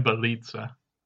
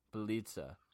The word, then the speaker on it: Bjelica: Maybe, um, and you're gonna have to help me with the pronunciation here, but Nemanja Valencia. Nemanja Bjelica. Bjelica.